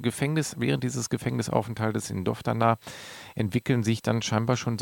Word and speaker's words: Doftana: Er lernt dann 1936 in, wenn ich es richtig ausspreche, Doftana. – In ja, dem, Doftana.